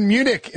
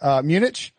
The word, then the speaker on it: Munich: Hey, Bayern Munich is playing right now. – Hey, you said it right. Good job. – Thank you. Munich.